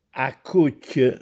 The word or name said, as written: Accùcchj